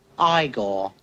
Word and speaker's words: Igor: You must be Igor. – No, it's pronounced Igor.